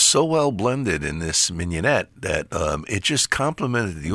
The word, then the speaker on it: mignonette: The minuet thing? Go ahead. – Minuet. – No, that's the dance. Mignonette. – Mignonette.